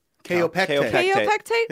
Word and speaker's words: Kaopectate: In fact, he said that they taste like, and I will say this wrong, calpectate? – Kaopectate. Yeah.